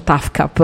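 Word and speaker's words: TAFKAP: A questo punto Prince non si fa più neanche chiamare Symbol, si fa chiamare con un acronimo che si pronuncia TAFCAP, ovvero sia The Artist Formerly Known as Prince, ovvero sia l'artista precedentemente conosciuto come Prince.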